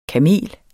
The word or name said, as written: kamel